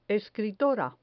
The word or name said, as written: escritora